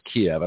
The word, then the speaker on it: Kiev: Brandon's abandoned the United States and is running off to Kiev, and that's pronounced Kiev.